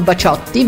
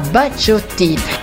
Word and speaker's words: Baciotti: Fatto sta che bisogna pronunciarlo alla francese, quindi non Baciotti ma Baciotti, che nel 1977 ottenne un buon successo anche in Italia con la canzone Blackjack.